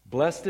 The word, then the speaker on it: blessed: Blessed is he, or sometimes we pronounce it Old English, blessed.